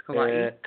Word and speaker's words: Kauai: That's how we found out that's how you say it. – Kauai? – Kauai. – Kauai. – Yeah. The whole time we've been saying Kauai. – Oh, I've been saying Kauai too. – Yeah. – It's Kauai.